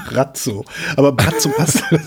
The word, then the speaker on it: Braco: Tatsächlich, Croatian Pronunciation, Brazzo.